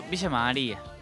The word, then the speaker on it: Villa María: Entonces, así si tengo que pronunciarlo, ¿cómo es? – Villa María.